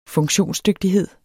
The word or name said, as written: funktionsdygtighed